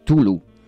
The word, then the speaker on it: Cthulhu: Yo siempre digo Cthulhu, pero es Tulu. Es que se escribe C-T-H-U-L-H-U, bla, bla, bla, bla, bla.